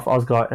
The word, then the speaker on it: Asghar: So Musharraf Asghar, M-U-S-H-A-R-A-F, Asghar, A-S-G-H-A-R.